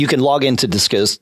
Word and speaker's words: Disqus: And I've seen um, there's a, a, comment or a discussion service, uh, Disqus, D-I-S-Q-U-S. – Discus, D- yeah, I, discuss.